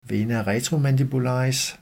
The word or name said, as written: v. retromandibularis